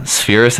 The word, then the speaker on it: Spheeris: And it's um, it's really um, that one's you know very depressing, of course, because of everything, but um Penelope uh, Spheeris, I think is how you yes. pronounce it.